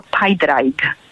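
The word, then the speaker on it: Pádraig: Natomiast Patryk, taki prawdziwy republikański Patryk, nie będzie Patrykiem, tylko Pajdrajk.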